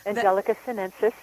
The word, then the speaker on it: Angelica sinensis: And some of these things, like you mentioned, I think it was the Angelica sinensis. Did I say that right? – Angelica sinensis, yes.